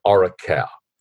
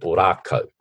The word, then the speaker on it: Orakau: It, was, it was the battle uh, um, of, um, well, I would have said Orakau, um, but the Māori pronounce it Orako, um, which is O R A K A U.